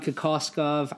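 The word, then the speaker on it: Kokoškov: At any rate, just to give those listeners out there a little bit of a background on Kokoskov, I know I'm saying it right now, so I'm gonna be confident moving forward.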